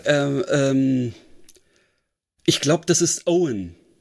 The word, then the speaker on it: Eoin: Also man schreibt ihn Eoin. – Ja, genau. – Also ist bestimmt irisch oder sowas. – Ja, genau. Ich glaube, er wird Owen ausgesprochen.